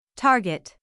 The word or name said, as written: target